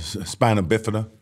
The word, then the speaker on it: Spina Bifida: The Spina Bona? – Spina Bifida. My youngest daughter. Yeah, it's a tongue tie. I mean, Spina Bifida.